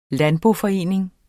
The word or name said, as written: landboforening